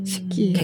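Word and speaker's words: 시키 (四季): 그러니까 일본 발음으로는 시키라고 하나요? – 시키라고 네, 하죠. 네. 예. – 네. – 시키.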